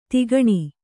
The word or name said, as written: ಧೊಕ್ಕನೆ